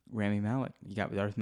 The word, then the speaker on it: Rami Malek: Rami Malek. – Oh, boy. – Is that his name? Did I say that correctly? – Rami Malek? – There you go.